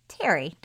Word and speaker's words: Thierry: And by the way you pronounce it, it's slightly confusing because it's spelled T-H-I-E-R-R-Y. – Well, he's French. – So it seems like it would be Thierry. But anyway, it's Terry. Don't you think that's how they say it? Terry? – You know, I have to be honest.